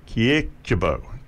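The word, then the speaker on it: Kyrkjebø: Surname is K-Y-R-K-J-E-B, O with a stroke through it. Kiekjebo, Sizzell Kiekjebo. So she just goes by the name Sizzell.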